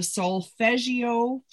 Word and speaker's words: solfeggio: But I came across these things called the solfeggio frequency. – Oh yeah. – I don't even know how to is that how you pronounce it? I don't solfeggio.